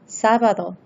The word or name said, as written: sabado